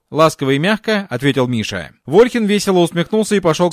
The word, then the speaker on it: Миша: Имя его произносили мягко с «я» на конце. «Миша!» — как он себя называл.